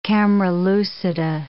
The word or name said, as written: camera lucida